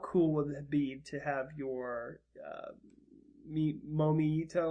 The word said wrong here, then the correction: Miitomo: or how do you say those again? – The Miitomos? – How cool would it be?